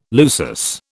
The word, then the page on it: lusus: British English